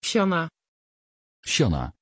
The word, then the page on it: Shana: Dutch